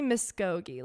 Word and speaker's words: Muscogee: It's definitely Muscogee, like almost pronounced K-O-H.